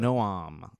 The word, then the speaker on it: Noam: Noam, is that how you pronounce it? – Noam. That's like, yeah.